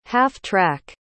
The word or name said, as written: half-track